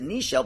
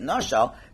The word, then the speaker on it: nashal: When such a situation arises, like over here, it's written Nun Shin Lamed, which we would normally pronounce it Nishel, but Hashem says pronounce it Nashal.